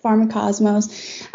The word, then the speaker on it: Pharmacosmos: Pharmacosmo, if I said that right? – Yeah, Pharmacosmos.